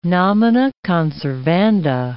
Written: nomina conservanda

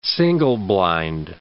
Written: single-blind

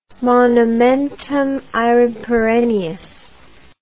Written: monumentumaereperennius

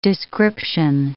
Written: description

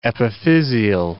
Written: epiphysial

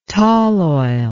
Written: tall oil

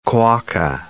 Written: quokka

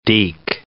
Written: deke

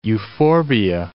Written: euphorbia

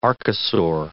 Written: archosaur

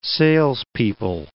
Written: salespeople

